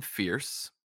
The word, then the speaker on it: Fears: And the book follows Quentin Fierce. It's spelled F-E-A-R-S. So like fears, but it's pronounced fierce.